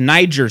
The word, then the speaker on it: Nyjer: Go into the bird seed store, and there is bird seed spelled N-Y-J-E-R. It's Niger seed. N-Y-J-E-R. Okay. It used to be spelled not that way. And you had to be very careful how you pronounced it, because it was not spelled like that.